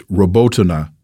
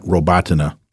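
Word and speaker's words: Robotyne: And let me correct myself it's Robotina, not Robotina. Please forgive that mispronunciation.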